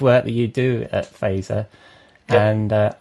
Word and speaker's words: Fazer: I am the Chief Strategy Officer at a branding agency in New York called Phaser. F-A-Z-E-R is how you spell it.